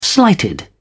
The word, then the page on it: slighted: British English